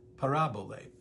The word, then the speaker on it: parabole: This is the Greek word parabole. Parabole, P-A-R-A-B-O-L-E. It's pronounced parabole.